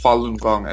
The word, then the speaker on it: Falun Gong: So when I came across Falun Gong, it's pronounced Falun Gong, as you pronounce it, Robert, very well.